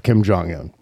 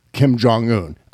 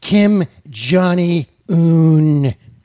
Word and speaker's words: Kim Jong-un: Kim Jong-un? I don't even believe... Donald Trump can't even pronounce Kim Jong-un. – How Kim Jong-un. – He says, Kim Johnny-un.